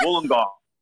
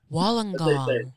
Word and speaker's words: Wollongong: Wollongong. – Wollongong. – I'm from a place called Wollongong.